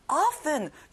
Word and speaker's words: often: often, 중요한 단어예요. 자주란 뜻이에요. T 발음은 안 나요.